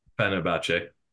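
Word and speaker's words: Fenerbahçe: Fenerbahce.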